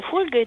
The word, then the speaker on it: фольга: И вот там сказано, что «фольга» – это польское слово, и ударение, соответственно, на первом слоге восходит к латинскому «фолиум».